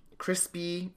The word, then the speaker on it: Chrisby: We hear about a girl named Crispy. It's spelled C-H-R-I-S-B-Y. So not crispy like bacon, but like spelled sort of like that.